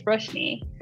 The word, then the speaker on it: Roshni: And even if they say it wrong, I'm sticking to Roshni. Like I'll correct them and be like, oh no, I'm sorry, it's Roshni.